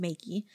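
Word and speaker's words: Magie: Basically, the point of this is that Elizabeth McGee, hopefully that's how her name is pronounced, Maggie.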